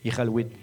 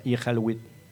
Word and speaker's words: Iqaluit: Mais les Q en Inuktitut, ça se prononce R. C'est Iqaluit. C'est comme un R, Iqaluit.